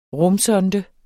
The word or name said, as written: rumsonde